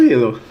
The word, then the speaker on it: Hillel: depois alguém souber uma pronúncia melhor me fala, mas eu acho que é Hillel, né, o primeiro nome do guitarrista? – Eu falo Hillel.